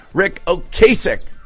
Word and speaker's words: Ocasek: According to AI, it is pronounced first syllable O. Second syllable, Cass, rhymes with mass or pass. – Ocasek. – Final syllable, ek.